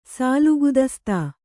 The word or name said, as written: ಸಾಲುಗುದಸ್ತಾ